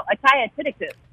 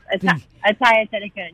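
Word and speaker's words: Atthaya Thitikul: Oh, Ataya Tidikun. – Yes, thank you. Ataya. – Yes, Ataya Tidikun.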